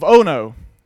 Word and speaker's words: Ono: "In the plain of Ono." That's really actually how you say it. I looked it up to be sure. It's kind of strange. It's not Ono, Ono.